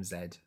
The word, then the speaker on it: Zed: But yeah, so we are introduced to Z or Zed. – I called him Zed. – His name, yeah, he said his name is Zed as well. It's spelled Zed.